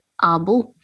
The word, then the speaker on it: Åbo: uh, that is how the Finnish Finnish people pronounce it, and the Swedish actually call it Åbo.